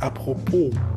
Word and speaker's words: apropos: Und das Wort apropos ist an sich falsch ausgesprochen. Es heißt eigentlich apropos. Das S wird nicht mitgeschrieben.